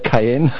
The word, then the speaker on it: Kanye: Kanye, how do you say it? Kanye. Yeah, he just went on streams of consciousness, sentences that sometimes lasted 10 minutes.